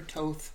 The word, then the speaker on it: Thoth: And one of the things I've learned is through my tarot studies, because I use a particular deck called the Thoth, T-H-O-T-H, or Thoth, potato, potato. Nobody speaks ancient Egyptian anymore. So anyway, the Thoth deck is how I pronounce it.